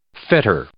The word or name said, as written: fetter